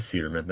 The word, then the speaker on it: Suderman: I thought it was Sunderman. There is no N. It is Suderman. That's so weird. I've been saying it wrong.